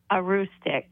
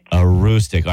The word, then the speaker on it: Aroostook: It's Aroostook County. – Aroostook. Aroostook. Hold on. Let me say it one more time. – Aroostook. – Aroostook.